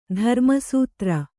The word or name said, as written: ಧರ್ಮಸೂತ್ರ